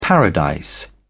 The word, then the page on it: paradise: British English